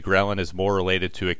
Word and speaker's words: ghrelin: I know leptin and, how do we say this, ghrelin? – Ghrelin. – Ghrelin, thank you.